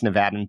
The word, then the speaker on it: Nevada: How do you pronounce our state's name? – It's Nevada.